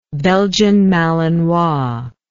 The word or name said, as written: belgian malinois